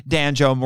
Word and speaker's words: Danjo: and exacts his wrath upon Danjo rhymes with banjo, and rescues our heroes.